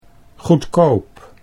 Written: goedkoop

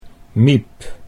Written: Miep

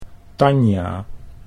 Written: Tanja

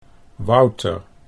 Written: Wouter

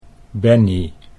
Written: Bennie

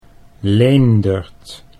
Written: Leendert

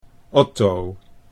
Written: Otto

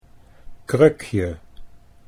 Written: krukje

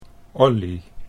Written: Ollie

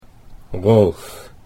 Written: Rolf

